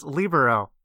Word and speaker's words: libero: but the word is correctly pronounced libero from the sport.